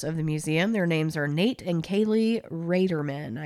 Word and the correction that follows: Ratterman: I believe. – Ratterman. – Ratterman. – I just heard them say it in an interview because okay. I was saying Raderman too. It's spelled like Rate.